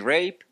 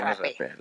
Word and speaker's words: rapé: Y aquí puedes decir rape. – ¿Rape o rapé? – Es no. De rapé, tiene acento, tiene acento, es de rapé.